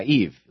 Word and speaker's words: Yves: Yves, I think it's how you say his name, Y-V-E-S, isn't that Yves? Yves? Yves? Oh, yeah, Yves. Like Yves Saint Laurent.